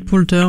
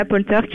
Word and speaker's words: Poulter: Donc Will, j'ai toujours du mal à le prononcer, Will Poulter, je sais pas trop comment on dit. – Poulter, ouais. – Voilà, Poulter qui joue du coup un des policiers est terrifiant.